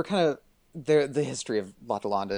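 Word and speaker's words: L'Atalante: Is it L'Adolante or L'Adolante? – I think it's L'Adolante. – L'Adolante, okay. – The A's in French are very right.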